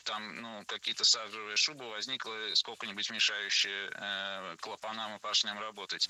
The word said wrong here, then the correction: клапанам: Клапанам и поршням. Оба слова произнесены неправильно. Ошибка однотипная. Должно быть клапаном и поршнем, так как перед нами слова с неподвижным ударением на основе. И в них ударение всегда падает на первый слог.